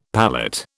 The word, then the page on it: palate: British English